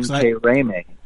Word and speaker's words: Intiraymi: So that's I-N-T-I-R-A-Y-M-I. – So now say it. How do you actually say that? – Intiraymi. Pronounce it Intiraymi.